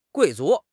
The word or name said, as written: 贵族